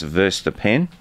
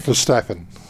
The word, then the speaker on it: Verstappen: And finally, for Formula One for the day, is world champion and current driver Max Verstappen also had Verstappen.